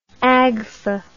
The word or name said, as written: agfa